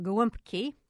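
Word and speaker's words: gołąbki: But she said her memories of big holiday gatherings were actually more about guumpki. Did I say that right? – You did, very well.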